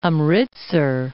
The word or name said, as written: Amritsar